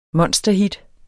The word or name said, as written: monsterhit